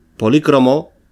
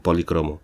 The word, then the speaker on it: polícromo: polícromo o también policromo.